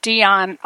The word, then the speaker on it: Deyan: So it's Beyond, B-E-Y-A-N, you said? Beyond? – D as in dog, Dion Audio. – D, spell it again, please. So sure. D-E-Y-A-N. – D-E-Y-A-N, Dion.